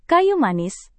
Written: kayu manis

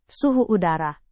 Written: suhu udara

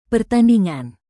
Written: pertandingan

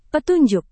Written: petunjuk

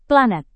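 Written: plat

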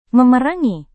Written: memerangi

